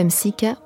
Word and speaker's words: Msika: Habiba Msika, ou plutôt Habiba Msika, prononcée en arabe, est une chanteuse, comédienne et indépendantiste tunisienne.